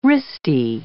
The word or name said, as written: wristy